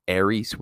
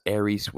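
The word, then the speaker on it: eris: In other words, Aries with that long E, the macron over the E, means you will be whatever the verb means. For instance, you will be led. Whereas Aries with a short E means you are whatever the verb means. For instance, you are led.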